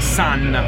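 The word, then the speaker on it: Sunn O))): So perché loro sono i Sun. La O, parentesi, non si pronuncia.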